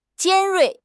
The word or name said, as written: 尖锐